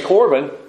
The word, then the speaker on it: Corban: If you can say Corban, you pronounce the Hebrew word.